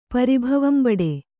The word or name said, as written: ಪರಿಭವಂಬಡು